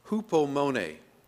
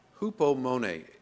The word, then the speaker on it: hupomone: So the word patience, the word most often used as patience in the New Testament actually comes from a Greek word pronounced, well, I can tell you how it's spelled. I'm not sure how it's pronounced, but I can try. It's hupomone, hupomone, H-U-P-O-M-O-N-E, hupomone.